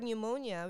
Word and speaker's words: pneumonia: In the word pneumonia, which is spelled P-N-E-U-M-O-N-I-A, the letter P is silent.